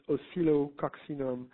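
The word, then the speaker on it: Oscillococcinum: The name is Oscillo coccinum.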